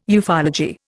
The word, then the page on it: ufology: Indian